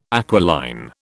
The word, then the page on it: aquiline: British English